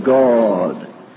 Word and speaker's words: God: Well, the Scots people say God with a long O, God.